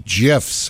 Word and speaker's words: GIF: When the guy that invented GIFs came out a couple years ago and said it's pronounced GIF, G-I-F is pronounced GIF, like the peanut butter.